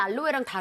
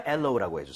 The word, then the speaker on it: aloe: 근데 이게 파닉스대로 근데 읽으면 알로에 느낌이 나긴 해요. – 알로에. 네. 하지만 엘로우라고 해주세요.